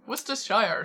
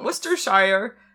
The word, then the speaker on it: Worcestershire: I think there's an extra syllable in there, you have to say, to prove you're... – There's so many. – To prove you're very British. – Yes. – Worcestershire sauce.